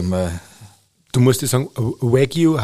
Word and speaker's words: Wagyu: Also aussprechen tut man es eigentlich Wagyu. – Mhm. – Also Wagyu, Wagyu sagen die ba Japaner.